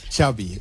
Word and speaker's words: Xavi: Xavi. Okay. – Yeah. – So I heard the Xavi like chocolate. – Yes. Okay. Thank you very much. – Xavi